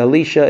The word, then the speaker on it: Elisha: So Elisha is? – Elisha. – Elisha. Okay.